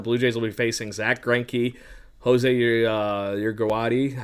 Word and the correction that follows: Urquidy: I forget how you say his name. – It's the, it, Urquidy.